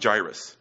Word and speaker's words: Jairus: Now behold, there came a man named, I pronounce it Jairus,